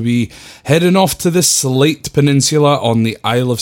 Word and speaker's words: Sleat: So now we're moving on to the Slate Peninsula, which is spelled S-L-E-A-T, but is pronounced as Slate,